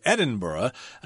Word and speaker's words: Edinburgh: But in Scotland, you're supposed to say Edinburgh. I can't think of any other place where the burg is pronounced Burgh, but you don't want to sound stupid.